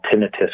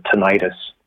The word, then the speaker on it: tinnitus: Another sign is potentially ringing in the ear, which we call tinnitus, or some people pronounce it tinnitus, which can oftentimes accompany hearing loss.